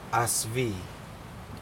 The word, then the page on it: asvii: Aneityum